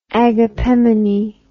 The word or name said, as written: agapemonite